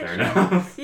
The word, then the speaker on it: Theranos: This sounds like some Theranos. Is that how you pronounce it? – Theranos.